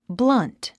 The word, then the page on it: blunt: blʌ’nt; ブラント